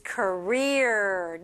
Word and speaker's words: career: 근데 한국 분들도 커리어 이렇게 하죠. But the pronunciation for native speakers is career. 네, 이렇게 돼야 돼요. Career.